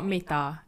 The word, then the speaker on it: Metta: It's called Metta. It's spelled as M-E-T-T-A.